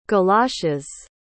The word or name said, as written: galoshes